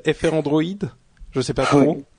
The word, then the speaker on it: Frandroid: Non, Frandroid. Il y a pas, comme j'ai déjà dit, il y a pas de prononciation officielle, donc on entendait FR Android et Frandroid, mais d'accord. Au final, ça revient à la même chose.